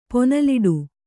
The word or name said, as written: ಪೊನಲಿಡು